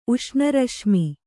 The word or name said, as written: ಉಷ್ಣರಶ್ಮಿ